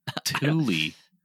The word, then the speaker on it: Thule: That's how you pronounce it. Thule.